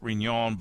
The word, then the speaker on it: Riñon: And then Riñon, R-I-N-O-N. R-I-N, that middle N has a little tilde over it, the little squiggle on top of the N, which means you pronounce it Riñon, but it's R-I-N-O-N, Riñon tomato.